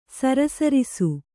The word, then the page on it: ಸರಸರಿಸು: Kannada